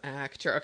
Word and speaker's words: Actura: It's Actura. Did I say that correctly? – Yeah. Well done. Nice. – Thank you. – No one does that. – I'm all phonetics, Kim. Actura.